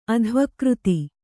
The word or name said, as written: ಅಧ್ವಕೃತಿ